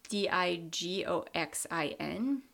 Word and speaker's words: digoxin: it's spelled D I G O X I N, so digoxin.